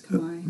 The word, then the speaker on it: Khmer: Yes, Khmer. – Am I saying it right? Okay. – You are. – Yeah. – It's spelled K-H-M-E-R.